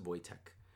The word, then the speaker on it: Wojtek: It's spelled differently. It's Polish, but it's spelled Wojtek, or it's pronounced Wojtek.